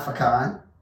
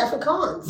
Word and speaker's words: Afrikaans: That's not Afrikaan. – No, it's actually Afrikaans.